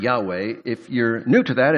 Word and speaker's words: Yahweh: What it actually was written was Jehovah, or even more accurately, the way it was probably pronounced is Yahweh.